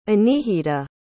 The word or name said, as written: anihita